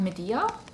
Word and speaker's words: Medea: In Greek mythology, we have Medea, if I'm pronouncing that correctly.